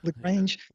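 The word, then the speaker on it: La Grange: Then ZZ Top came out with, how do you say it, LaGrange? – LaGrange.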